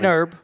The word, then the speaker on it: Herb: Morgan Herb. – Herb, okay, because sometimes I'll say the H, and then you look at me like I'm saying it wrong.